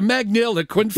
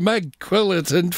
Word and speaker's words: magniloquent: We've got a magniloquent for sale, Magilla, Magquillitant for sale.